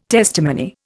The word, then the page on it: testimony: Indian